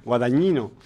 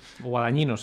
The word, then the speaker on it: Guadagnino: Guadagnino. – Guadagnino, sí, la pronunciación italiana debe ser.